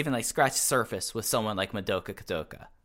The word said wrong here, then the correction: Kakuta: You know, Kikut. I got the name right when I was pronouncing it before. Madoka Kakuta.